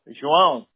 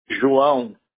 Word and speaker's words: João: No, the first one, J-O-A-O, is my, I go by Lucas because it's easy, but my first name is actually J-O-A-O, João.